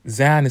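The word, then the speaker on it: Xan: Zan is spelled with an X, X-A-N.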